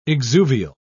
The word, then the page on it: exuvial: British English